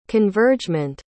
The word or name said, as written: convergement